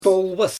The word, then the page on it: tolbas: Xyzyl